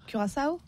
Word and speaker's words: Curaçao: Donc comment on prononce ça? – Curaçao. – Curaçao. – Ah oui, Curaçao, j'aime bien.